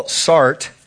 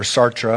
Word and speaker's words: Sartre: I mentioned Jean-Paul Sartre last week, or Sartre, I've heard it pronounced different ways.